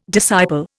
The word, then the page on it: disciple: Indian